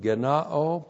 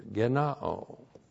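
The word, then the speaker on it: gennaō: This is the perfect passive indicative of genao plus the prepositional phrase ek plus the genitive singular of theos, with the definite article. Now we're going to have this verb used several times here. Genao. G-E-N-N-A long O.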